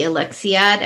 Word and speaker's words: Alexiad: I mean, hello, she wrote something called The Alexiad.